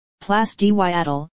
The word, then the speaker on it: Place du Châtelet: The Place du Châtelet, French pronunciation, Place du Châtelet,